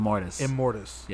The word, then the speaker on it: Immortus: And how you say his name again? – Immortus. Yeah.